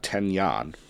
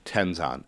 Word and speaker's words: Tenzan: Not Tenyan, Tenzan.